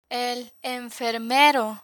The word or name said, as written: el enfermero